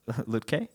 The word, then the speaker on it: Lütke: What's his last name? – Lütke. – Oh, is that how you say it? – Yeah.